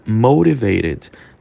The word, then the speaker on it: motivated: Ó, repara, motivated. Como é que se fala essa palavra, né? Ela coloca uns R's aí no meio quando a gente vai pronunciar. Motivated. O T se transforma num som de R.